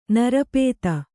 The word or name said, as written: ನಿರುಪಾಧಿ